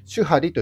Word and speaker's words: 守破離: 僕、禅の言葉って結構好きなんですけども、禅の言葉で守破離という言葉があります。守る、破る、離れると書いて守破離と読みます。